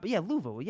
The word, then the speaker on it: Luvu: I think, it's four, if you can't pronounce a four-letter name, Luvu. – You got, Luvu.